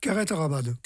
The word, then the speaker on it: carreto ramado: Alors, en provençal, c'est Philippe qui me l'a dit, hein, carreto ramado, c'est ça? – Carrette ramade. – Carrette ramade.